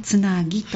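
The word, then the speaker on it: つな樹: つなぎと読むそうですから、はい、こちらで検索してみてください。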